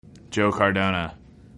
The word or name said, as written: Joe Cardona